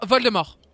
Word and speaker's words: Voldemort: De Voldemort. Eh, parce qu'on prononce pas le T parce que c'est Voldemort, comme, parce que c'est Voldemort littéralement en français.